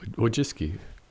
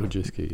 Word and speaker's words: Wojcicki: Susan Wojcicki, or however you pronounce it. How do you pronounce her last name? – Wojcicki. – There we go. – Wojcicki, yeah.